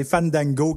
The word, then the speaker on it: Fandango: Il faut aspirer les A comme Fandango.